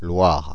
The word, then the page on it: Loire: [lwaʁ]; Paris